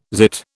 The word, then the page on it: zit: British English